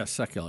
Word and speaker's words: Sekulow: All right, so we know that that we heard Jay, you know, Sekulow. Is that how you say his name? – Yeah, Sekulow.